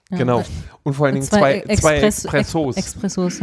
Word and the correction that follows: Espresso: okay, ne, weiß man äh, Espresso.